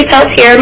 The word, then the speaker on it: Peltier: My name is Ashley Peltier. My last name is spelled P-E-L-T-I-E-R, and I'm here today representing myself.